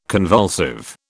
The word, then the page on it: convulsive: British English